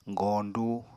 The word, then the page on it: goñdu: Apatani